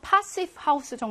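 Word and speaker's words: Passivhaus: 패시브 하우스인데 뭐 독일어로는요. 파시브 하우스 정도로 발음해야겠다.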